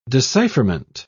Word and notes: decipherment: British English

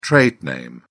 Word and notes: trade name: British English

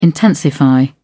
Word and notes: intensify: British English

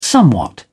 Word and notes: somewhat: British English

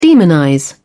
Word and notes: demonize: British English

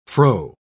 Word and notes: froe: British English

